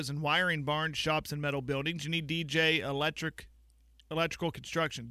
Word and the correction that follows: DNJ: DNJ. Say it right.